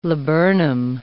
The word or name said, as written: laburnum